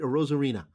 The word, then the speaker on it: Arozarena: Next guy, Randy Arozarena. Did I say that right? Arozarena. – Yeah.